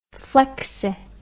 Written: flexi

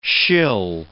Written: shill

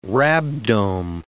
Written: rhabdome

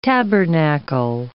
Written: tabernacle

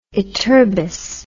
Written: ytterbous

